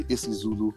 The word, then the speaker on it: isiZulu: And the interesting thing about I'll say isiZulu to pronounce it properly.